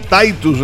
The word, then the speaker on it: Tytus: Esta banda Titus o Titus, le dicen también, se escribe T-Y-T-U-S, es de Huila.